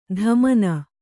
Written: ಧಮನ

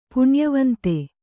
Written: ಪುಣ್ಯವಂತೆ

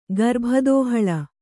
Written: ಗರ್ಭದೋಹಳ

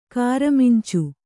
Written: ಕಾರಮಿಂಚು

